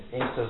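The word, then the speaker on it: Anstruther: If you go to Ainster, that's what Fife people call it, isn't it? Foreigners call it Ainster, I don't know what it means. Yeah, Ainster is the Fife pronunciation, yeah.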